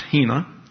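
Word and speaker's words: hina (ἵνα): There is an important word in John 3:16, a small, simple word pronounced hina.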